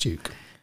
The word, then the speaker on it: Duc: It's spelled D-U-C, by the way. So not Duke, as in. – Yes, but it's pronounced Duke.